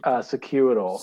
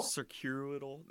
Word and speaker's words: Circuital: I don't think I saw them until, was it Circular Tello? How do you pronounce that? Circular? – Circuital. – Circuital. That's the one.